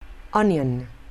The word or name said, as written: onion